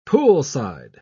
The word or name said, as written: poolside